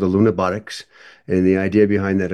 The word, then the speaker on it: Lunabotics: I was gonna ask you about the NASA Lunabotics Challenge. I think I'm saying that right. Can you tell me about it? – That is correct, and that's what Oliver was talking about.